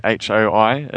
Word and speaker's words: Sukhoi: I mean, do you know how, I don't know how I'm saying it right, Sukhoi, you know, Sukhoi? Sukhoi.